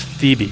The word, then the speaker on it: Phoebe: And how would you say this? Phoebe? – It's Phoebe. Phoebe. It's the name of a girl.